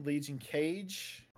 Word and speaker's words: LegionKaje: I am LegionKaje. That's how it's pronounced. Cage, not KG or whatever. But I don't. Yeah, KJ. I like Kaje.